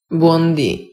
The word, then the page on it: buondi: Italian